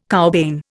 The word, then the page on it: cowbane: Indian